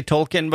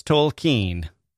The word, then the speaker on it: Tolkien: Did I say Tolkien before? It's an old habit. That's how I grew up pronouncing his name. I believe it's Tolkien.